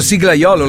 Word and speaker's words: siglaiolo: Perché ho detto siglaiolo, l'ho detto giusto. – Giusto. – Perché tu avevi detto sigaiolo, Fabri, devo scriverlo in stampatello. Tu hai detto sigaiolo.